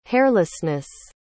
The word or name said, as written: hairlessness